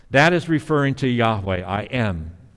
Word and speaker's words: Yahweh: You know, in Hebrew, that name, according to scholars, is pronounced probably Yahweh. It was inappropriately translated earlier on in earlier translations, Jehovah. But it is really Yahweh.